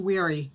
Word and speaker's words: Weiri: Okay, now how, how about the last name though? Because I don't want to butcher that either. I want to make sure I got it oh, right. Just like, like at the end of the day, when you're tired, it's weary.